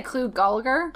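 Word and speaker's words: Clu Gulager: C-L-U? Gulager? – I would say Clue Gulager.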